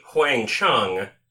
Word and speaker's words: Huang: And when I say Huang Chung, it's because it's spelled H-U-A-N-G, Chung.